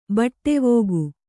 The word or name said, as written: ಬಟ್ಟೆವೋಗು